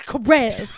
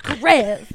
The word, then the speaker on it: Karev: I liked to say his name the way Bailey said it, which was Dr. Karev. Dr. Karev. – I mean, why not? Bailey is an icon as well. – She emphasizes the rev.